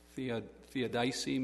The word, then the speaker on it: theodicy: It's theosity. No. Theodicy, maybe. It's T-H-E-O-D-I-C-Y.